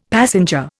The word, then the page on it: passenger: Indian